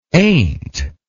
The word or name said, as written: aint